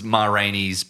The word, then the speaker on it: Ma Rainey: You mispronounced Mario. – Oh, sorry, Mario's. – Mario's. – It was Ma Rainey's Black Bottom.